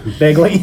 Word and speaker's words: Bingley: Ed like, Bingley Jr. Bingley. – Bing like Sing and Lee.